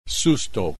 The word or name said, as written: susto